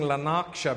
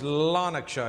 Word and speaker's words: Lanarkshire: We were saying Lanarkshire because the R is were just before the K, but they told us, no, it's Lanaksha.